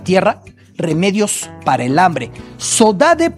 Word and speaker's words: saudade: Forma de decir saudade, una palabra que en Brasil conocemos mucho, saudade, saudade, es un término portugués que así se pronuncia en el archipiélago, saudade, con ese dialecto caboverdiano.